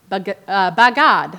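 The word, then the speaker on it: bagad (בָּגַד): And the word is bagad. I had to look up the pronunciation too. So the word is bagad,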